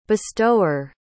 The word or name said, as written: bestower